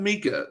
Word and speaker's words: Micah: Number one, I know it's spelled like Micah, but she continually calls him Mika. So I'm going to try to continue to say Mika in this episode. But if I say Micah, I mean Mika.